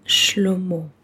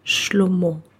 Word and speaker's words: Shlomo: The meaning of the name Shlomo, in this pronunciation, Shlomo, is His peace.